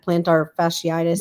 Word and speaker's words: plantar fasciitis: My husband had plantar fasciitis. Yep. I think I said that right. Yep.